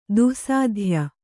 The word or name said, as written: ದುಸ್ಸಾಧ್ಯ